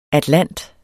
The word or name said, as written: atlant